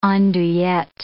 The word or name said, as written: andouillette